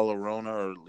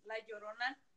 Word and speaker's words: La Llorona: La Llorona. Llorona. Llorona. – Okay. – Yeah, I guess that's how you say it. – The double L, it's like you pronounce it like a Y.